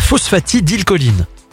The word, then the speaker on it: phosphatidylcholine: la phosphatidylcholine.